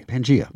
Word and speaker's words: Pangaea: P A N G A E A. Pangea. We're going to go with that. Oh. Never never really knew exactly how to say it, but that sounds good to me.